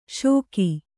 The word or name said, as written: ಷೋಕಿ